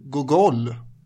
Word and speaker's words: Gogol: Jonas Nordin har förklarat för mig att man uttalar det Gogol.